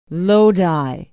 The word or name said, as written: Lodi